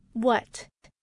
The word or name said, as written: what